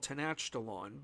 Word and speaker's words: Tenochtitlan: But as I got older, I learned it was actually called Tenochtitlan.